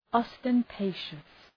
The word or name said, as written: ostentatious